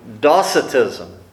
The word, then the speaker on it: Docetism: The first one was called Docetism, or Docetism. The C is actually pronounced like a K.